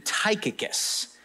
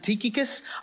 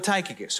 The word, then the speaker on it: Tychicus: The first name that Paul references here is a guy named Tychicus. Tychicus. Now, some people pronounce it Tychicus. I'm not going to do that. Sounds weird. I'm going to go with Tychicus, all right?